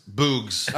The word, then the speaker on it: Boogs: Which is spelled like Boogs, like boogers.